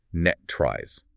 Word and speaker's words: NTREIS: But the documents explicitly instruct that this is pronounced net tries. And they specify that the last syllable rhymes with rust.